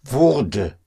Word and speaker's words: Wurde: To make that sound distinction between Würde for would and Wurde for was getting, was becoming, was getting. So, mm. ah, it was mm. getting dark. Es, es wurde.